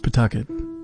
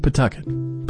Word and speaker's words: Pawtucket: It is spelled Pawtucket, but it is pronounced Pawtucket. The W is silent, maybe even the A. Pawtucket, perfect for freshening up any Nantucket-based dirty limerick.